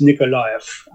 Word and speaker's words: Nikolaev: It's difficult to look up sometimes because it's spelt so many different ways, but it's Nikolaev, and it's one of the key ports that Putin has been trying to get hold of.